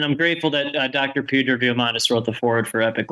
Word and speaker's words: Diamandis: Peter Diamandis.